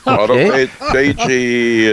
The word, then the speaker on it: autophagy: Let me take a running start at this word, autophagy. – That's one way of pronouncing it. – Okay. – Autophagy. – Is it autophagy? – Autophagy. – Okay.